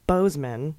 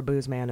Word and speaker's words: Boozman: Senator Boozman is pronounced Bozeman, and moron is pronounced Moran, though I quite prefer Boozman and moron.